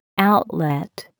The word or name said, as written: outlet